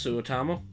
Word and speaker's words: Suotamo: Jonas Suotamo.